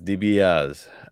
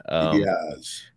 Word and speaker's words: DiBiase: They never, you know, so is it DiBiase or is it DiBiase? I mean, it's spelled like DiBiase, but it's not pronounced that way. – It's DiBiaz. – DiBiaz.